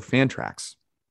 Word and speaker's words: Fantrax: Also, Fantrax rhymes with anthrax.